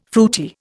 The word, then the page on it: fruity: Indian